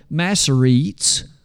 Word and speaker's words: Masoretes: And therefore, from an early point, there was a group of Masoretes, and that's the way you pronounce it.